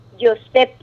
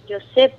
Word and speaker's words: Josep: donde dicen que José deriva de Josep, con PH al final. Entonces, en español es muy difícil pronunciar esa P al final.